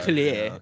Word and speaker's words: Atelier: Yeah, you gotta like put the if it's French, then I know the pronunciation, because it's Atelier in that case.